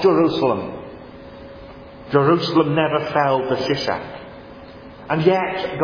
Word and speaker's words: Shishak: My wrath shall not be poured out on Jerusalem by the hand of Shishak. The Pharaoh Shishak, the Egyptian pronunciation is more like Shoshenk, but you know how it goes when you take a word from one language into another.